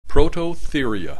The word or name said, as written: Prototheria